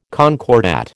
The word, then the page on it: concordat: American English